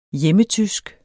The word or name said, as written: hjemmetysk